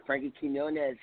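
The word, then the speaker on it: Frankie Quiñones: Now, are you still touring with Frankie Quinones? Am I pronouncing his name right? – You are Frankie Quinones.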